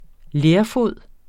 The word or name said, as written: lerfod